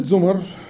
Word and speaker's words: زمر: كلمة زمر جمع إيه عشان ناس كتير من الشباب وحتى متدينين للأسف بينطقوا غلط جمع زمرة زمرة وزمر مظبوط